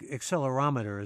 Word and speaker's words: accelerometer: In fact, accelerometer is the right pronunciation. I didn't want to botch that again.